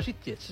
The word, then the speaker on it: Sitges: No ha dicho Sitges, ha dicho Sitges. – Lo ha dicho muy bien. – Lo ha dicho muy buena pronunciación catalana.